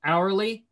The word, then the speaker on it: hourly: Hourly habitats are back. You know that hourly is kind of like a silent H?